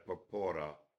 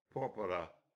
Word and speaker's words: Porpora: So I said Porpora, and Vern was in the class, and he embarrassed me by saying, Professor Argento, Signor Porpora. He corrected my pronunciation because he was doing a dissertation on that composer.